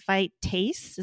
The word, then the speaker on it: phytase: Phytate and phytase.